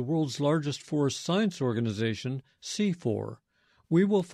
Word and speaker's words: CIFOR: And C4 is an acronym, and it's spelled C-I-F-O-R.